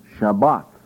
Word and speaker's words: Shabbat: It is pronounced Shabbat. Shabbat. There are those who take away the H at the end and they call it Shabbat.